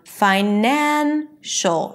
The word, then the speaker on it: financial: Same thing with finance. The primary stress is on finance. Once I add the suffix, then it turns into financial. From fine, it shifts to the second syllable, nan, the one syllable before the suffix.